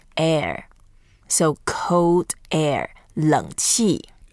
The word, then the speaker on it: 冷气: To say air conditioning in Chinese, we say 冷气. – 冷气. – 冷气. – 冷气. – 冷 means cold. It's a third tone, 冷.